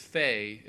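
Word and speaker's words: Fe: And there's one, I believe it's called pronounced Faye. It's just titled F-E.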